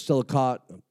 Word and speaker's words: Selichot: Selichot, said it wrong. It's S-E-L-I-C-H-O-T prayers.